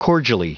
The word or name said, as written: cordially